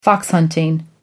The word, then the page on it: fox hunting: American English